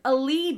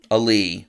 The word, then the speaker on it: alee: And that's how I started discovering words like, I'd never heard the word allee before. It means... – Can you spell that? – A-L-E-E. – Allee.